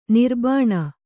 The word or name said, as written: ನಿರ್ಬಾಣ